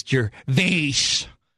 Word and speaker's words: Gervais: I was just corrected. His pronunciation of his name is Gervais.